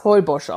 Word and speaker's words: Holbox: Geschrieben wird es wie die hohle Box, also Hohlbox, H-O-L-B-O-X. Und wir glauben, dass das Holbosch ausgesprochen wird, Bin mir ziemlich sicher.